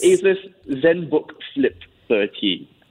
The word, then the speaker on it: Asus: Asus. All right. – Asus? – As a comparison, Asus. – Asus. – It's actually pronounced Asus, you know. I know they say, you know, okay, Asus. But it's Asus.